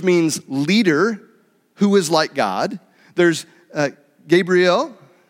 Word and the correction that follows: Gabriel: Am I saying that right? Gabriel, Sorry, my emphasis got on the wrong syllable there, uh, which means the great one, and then Lucifer, which means morning star.